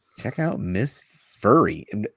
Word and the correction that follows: Miss Fury: Am I saying that right? That's Miss Furry. – Miss Fury. – Fury? All right, Miss Fury.